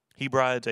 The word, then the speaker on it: Hebrides: In 1839, there were two men from the London Missionary Society that landed in how do you say that? New Hebrides? Hebrides, H-E-B-R-I-D-E-S, Hebrides.